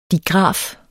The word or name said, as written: digraf